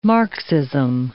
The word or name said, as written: Marxism